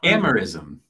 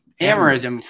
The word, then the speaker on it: Amorism: And then we have these other views like amorphism. How do you say it? What's the proper amerism? – Amerism. Yeah. I was way off.